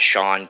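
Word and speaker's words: Shawn: So, and that's at Sean K. Rooker. – And last name, first name is spelled S-H-A-W-N-K as in kangaroo, Rooker, R-O-O-K-E-R.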